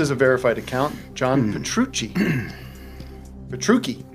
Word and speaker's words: Petrucci: Petrucci? How do you say that, Matt? – Petrucci.